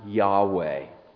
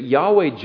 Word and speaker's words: Yahweh: This is the name of God, which is four letters in Hebrew that are probably pronounced Yahweh.